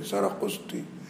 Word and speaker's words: سرقسطي: سرقسطي مثلا، سرقسطة مش عارف تقراه، النوبختي، يا نوبختي ولا نوبختي، عشان انت هتقلبها الى حروف انجليزيه هتبقى نوبختي ولا نوبختي. هيقول لك هي بتتنطق سرقسطي،